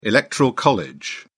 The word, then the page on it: electoral college: British English